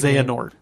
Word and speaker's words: Xehanort: That's not going to be the actual name of the Xehanort.